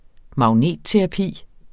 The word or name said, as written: magnetterapi